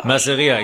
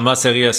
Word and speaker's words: Masseria: Masera Schiuma. – Masseria, genau, Masseria Schiuma. Masseria, ja.